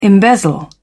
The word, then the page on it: embezzle: British English